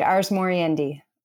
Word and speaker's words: ars moriendi: So you talk in the very early on in the book, and I may be saying this wrong. I guess it's Latin. Say it for me. Ars moriendi. – That's perfect. Ars moriendi.